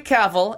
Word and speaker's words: Cavill: Henry Cavill, and it rhymes with travel.